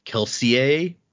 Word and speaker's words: Kelsier: And so the names, the technical correct pronunciation of the names is more French. So if you were to try to be technically correct, this would be like Kelsier. But no one says it like that. – Kelsier! Like, well, Even Brandon in interviews and stuff calls him Kelsier, but technically the pronunciation would be Kelsier.